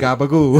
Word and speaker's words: gabagool: And, uh, you know, Capicola, you know, in New uh, Jersey, gabagool. it's gabagool. gabagool.